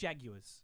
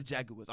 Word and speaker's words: Jaguars: No, it's the Jaguars. – The Jaguars. I'm sorry. I mispronounced that. I forgot that. That is how Pittsburgh pronounces that team.